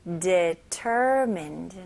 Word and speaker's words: determined: determined. 네, D-E로 시작하지만 D가 아니고요. D, 약간 DI처럼 읽으셔야 돼요. determined. mind. mind가 아니에요. mind. determined.